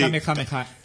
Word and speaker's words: Kamehameha: Kameja, muy apropiado. – De los gritos de uno de las de los trucos, creo recordar que tenía el sí, Kamehameha. Sí. – Con K-M-E-H-A, se escribe. – K-M-E-H-A.